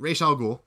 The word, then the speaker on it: Ra's al Ghul: I'm just saying Ra's. That's how it's said in the cartoon. So there you go. – Yeah. – So I'll say it. Ra's al Ghul.